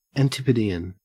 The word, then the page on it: antipodean: Australian English